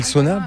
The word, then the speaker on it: sauna: Et le sauna, peut-être aussi. – Oui. – Le sauna. – Sauna, voilà. – J'ai appris maintenant qu'il fallait prononcer chaque lettre.